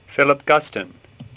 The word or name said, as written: Guston